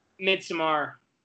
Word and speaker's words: Midsommar: Is it pronounced Midsummer or Midsommar? Because one of us pronounced it. I thought it was Midsommar, but... – Midsommar. – All right. – No, the actual event is Midsummer, though.